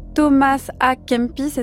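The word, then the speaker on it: Thomas a Kempis: Je ne sais pas si vous connaissez Thomas A. Kempis, est-ce que je le prononce bien cette fois-ci? Très bien.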